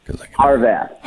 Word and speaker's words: Harvath: I hate your character's Harvath. – Last name. – Harvath.